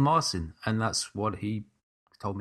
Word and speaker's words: Marcin: And as we said, the Polish composer's name is tricky for us ignorant English types to pronounce, but his first name is Marcin. Or it could be Markin. I could be getting that wrong as well. – No, it is Marcin.